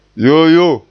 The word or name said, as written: yoo yoo